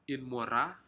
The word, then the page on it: inmora: Aneityum